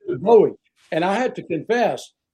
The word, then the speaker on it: Bowie: I'd say it, and I'm like, no, it's Bowie, it's Bowie. But did you know that David Bowie, the singer, he took that name from Bowie? – Oh.